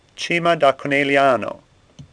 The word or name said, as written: Cima